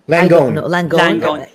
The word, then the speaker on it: Langone: Langone.